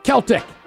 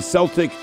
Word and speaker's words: Celtic: They say it wrong, but we accept it. – Okay. Yeah. – My kid is in the Celtic soccer, and I keep saying Celtic, and everyone always corrects me.